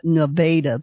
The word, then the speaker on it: Nevada: Is that how you say it, or is it not Nevada there? – Nevada.